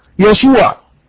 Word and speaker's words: يشوع: النقطة رقم اثنين، النقطة رقم اثنين، قلنا ان يسوع في العبرية تنطق يشوع.